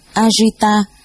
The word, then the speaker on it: Ajita: Ngài A Dật Đa, tiếng Ấn Độ đọc là Ajita, chữ Hán là Vô Năng Thắng, là tên riêng của Ngài Di Lặc Bồ Tát, tiếng Ấn Độ đọc là Maitreya.